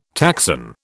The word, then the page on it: taxon: British English